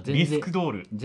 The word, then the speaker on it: 着せ替え人形: 着せ替え人形と書いて、なんとかドールって読むんだけど、リスクドール。